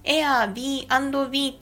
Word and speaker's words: Airbnb: 前回 31 箱目でゲストハウスのサイトのご紹介をさせていただいたんですが、そちらで私ずっと Airbnb というふうに言ってね、ご紹介をさせていただいてたんですけれども、正しくは Airb&B と読むんだそうです。 BNB ってね、ローマ字では BNB って書いてあるんですけれども、そう書いて B&B って読むんだよっていうのを聞いてくださってる方から教えていただきましたので、こちらで訂正とお詫びを申し上げたいと思います。